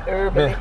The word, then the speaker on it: Herbally: You don't pronounce the H. – Herbally.